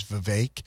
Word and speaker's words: Vivek: In Ohio, you've got Vivek Ramaswamy, and I interviewed Vivek, and that's how I know that the actual proper pronunciation is Vivek, because he told me it rhymes with cake, and I love cake.